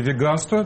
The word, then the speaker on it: веганство: То есть это в академический словарь, да, правильное ударение – веганство. Ну, то есть по аналогии с вегетарианством. То есть это русский язык, русское слово – веганство.